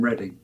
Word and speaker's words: Reading: Reading, yes. All right. – Yeah, well, it's pronounced Reading in England.